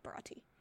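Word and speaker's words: Baratie: Baratai? – I think it's Baratai. Barati. No, Barati. – Barati? Okay. – Yeah, Barati.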